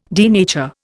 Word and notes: denature: Indian

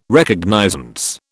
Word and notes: Recognizance: British English

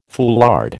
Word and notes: foulard: American English

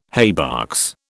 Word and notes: haybox: British English

